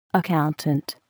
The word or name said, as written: accountant